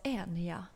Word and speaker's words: Enya: En ny. – Ja, men det uttalas Enya.